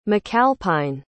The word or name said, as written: macalpine